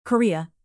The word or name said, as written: korea north